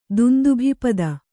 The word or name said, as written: ದುಂದುಭಿಪದ